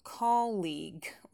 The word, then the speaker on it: colleague: И произносим тоже colleague. Ударение на первый слог. Долгий и, но безударный.